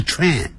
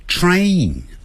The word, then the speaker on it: train: 不要念成 a，不要 train station，不是 train，叫 tray，先念 tray tray，再来一个 n 的音， train station，啊啊哈，这样就对了。